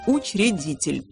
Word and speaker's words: учредитель: В слове «учредитель» после «Ч» нет гласных. И читается, и пишется как «учредитель». Конечно, в устной речи между согласными «Ч» и «Р» часто закрадывается некий призвук, как будто капля воды затекает. По идее, этого можно избежать, если произносить слова «учредитель», «учредительный», «учреждение» более энергично.